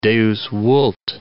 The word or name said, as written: Deus vult